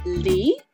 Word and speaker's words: Lea: Lee.